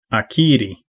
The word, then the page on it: acquire: /əˈkwaɪɚ/; American English